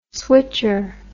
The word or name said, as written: switcher